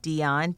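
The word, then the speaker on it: Deon: I can be reached on Twitter, Ebony, E-B-O-N-I, Dion, D-E-O-N, W-S-B. So Ebony Dion, W-S-B on Twitter. Facebook, it's Ebony Dion, W-S-B TV.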